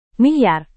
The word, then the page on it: milyar: Indonesian